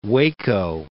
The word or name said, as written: Waco